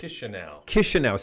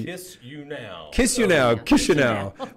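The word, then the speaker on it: Chișinău: But if I was living in Chisinau, or how do you say it? – Chisinau. – See, I'm only... – Kiss you now.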